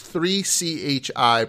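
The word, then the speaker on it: 3Chi: It's 3-CHI, pronounced 3 Chi.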